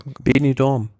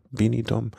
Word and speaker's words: Benidorm: Und da ist das, wie spricht man es genau aus? – Benidorm. – Benidorm.